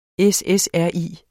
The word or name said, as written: SSRI